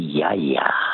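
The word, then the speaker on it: yaya (γιαγιά): In modern Greek, it's actually yaya.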